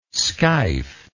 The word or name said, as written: skeif